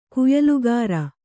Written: ಕುಯ್ಲುಗಾರ

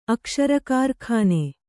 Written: ಅಕ್ಷರಕಾರ್ಖಾನೆ

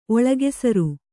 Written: ಒಳಗೆಸರು

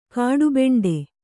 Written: ಕಡುಬಂಡೆ

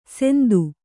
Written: ಸೆಂದು

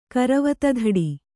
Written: ಕರವತಧಡಿ

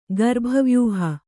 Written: ಗರ್ಭವ್ಯೂಹ

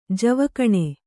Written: ಜೀವಗುಣ

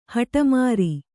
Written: ಹಠಮಾರಿ

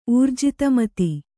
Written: ಊರ್ಜಿತಮತಿ

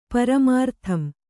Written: ಪರಮಾರ್ಥಂ

